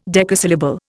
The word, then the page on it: decasyllable: Indian